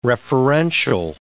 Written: referential

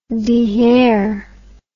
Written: dehair

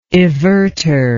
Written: evertor

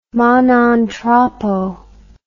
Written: ma non troppo

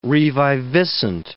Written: reviviscent

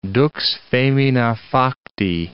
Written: dux femina facti

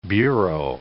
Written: bureau

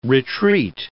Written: retreat

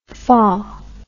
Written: fah

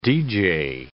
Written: deejay

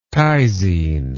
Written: tyzine

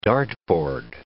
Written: dartboard

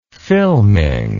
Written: filming

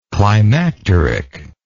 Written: climacterically

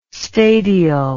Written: stadial

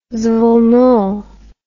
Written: zveno

